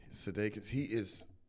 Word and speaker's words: Sudeikis: Jason Sudeikis, how you say his name? Last name? – Sudeikis.